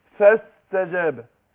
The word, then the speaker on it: فاستجاب: فما اجي اقول فاستجاب، فاست نطقت بعد الفاء ايه؟ سين، مش كده؟ يبقى هنا الايه؟ الالف هنا او الهمزه هنا ايه؟ – وصل، سقطت، وقعت. فاقول فاستجاب، يبقى نطقنا بعد الفاء سين.